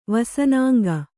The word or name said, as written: ವಸನಾಂಗ